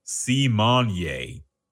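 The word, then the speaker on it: Symoné: Raven Simone corrected everybody this week. She said her name is actually pronounced C. Monier.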